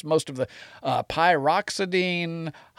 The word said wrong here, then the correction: pyridoxine: hydrochloride. That's pyridoxine hydrochloride.